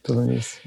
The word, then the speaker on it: Philonise: Well, I'm still thinking about the testimony before Congress last week of the brother of George Floyd, Philonise Floyd. Am I pronouncing his name right? – Philonise, yeah.